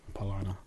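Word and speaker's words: Paulaner: Yeah, as we're finishing up this polliner, we'll get the pronunciation. – Paulana. – So we were kind of emphasizing the wrong syllable.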